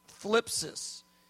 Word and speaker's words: thlipsis: It's if you want to spell it, if you're taking notes, it's T-H-L-I-P-S-I-S, thlipsis. Thlipsis. 45 times. And I just want to say this real clear. I've read every verse slowly in context that uses this term, thlipsis.